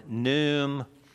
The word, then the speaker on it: Khnum: Until you understand the context of Egyptian worship, last week I introduced you to a god of the Nile who is called Nu or Num. Sometimes you see him spelled like K-H-N-U-M.